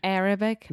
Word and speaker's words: Arabic: Arabic. The stress is on the first part, Arabic.